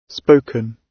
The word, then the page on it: spoken: British English